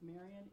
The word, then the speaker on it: Marian: My name is Marian, M-A-R-I-A-N, don't forget the dot or I won't get it, Marian.Hatcher, H-A-T-C-H-E-R.